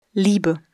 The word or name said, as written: Liebe